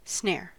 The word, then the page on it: snare: American English